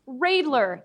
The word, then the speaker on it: Readler: is that it's pronounced Radler, not Readler. And for years after meeting him, having the correct pronunciation explained to me, I would still have people go, no, it's Readler.